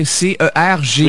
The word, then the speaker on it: Cergy: Est-ce que je le prononce bien? Cergy. – Oui. – des violences. – Vous prononcez parfaitement bien.